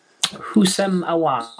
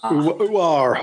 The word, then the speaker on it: Houssem Aouar: How do you pronounce his name? I can't remember his name. – Houssem Aouar. – Aouar.